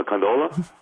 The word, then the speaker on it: gondola: A gondola? Gondola? – There it is! – There we go! A variety of different pronunciations. – You had the emphasis thing. – On the wrong syllable.